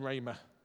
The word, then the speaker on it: rhema: And it's written rhema, and it's pronounced rhema.